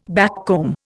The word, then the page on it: backcomb: Indian